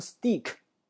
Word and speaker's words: steak: 牛排叫 steak，S-T-E-A-K，注意这个单词的发音啊，它很多学生会读成 steak，恭喜你不对啊，他读 steak，steak，还有的学生会读成 steak，它不是 steak，它是 steak，T 呢要读成 D 的发音，为什么？